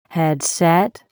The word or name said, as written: headset